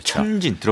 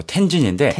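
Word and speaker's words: 텐진 (天津): 천진 들어봤죠. – 예, 현지 발음으로 텐진인데 텐진.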